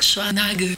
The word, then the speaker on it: Schwarzenegger: Inclusive eu descobri com meu irmão que a gente fala errado o sobrenome desse cara. O sobrenome tá falado errado. – Como é que fala? – Schwarzenegger. Schwarzenegger. É, meu irmão tava explicando isso daí. Em alemão se pronuncia assim. – Schwarzenegger. – Schwarzenegger. – Schwarzenegger. E não Schwarzenegger. não existe no alemão.